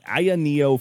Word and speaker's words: AYANEO: Aya Neo, A-Y-A-N-E-O.